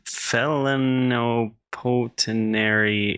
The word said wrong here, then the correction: plenipotentiary: instrument. – Plenipotentiary. – Okay. – Plenipotentiary. – Let's say it again.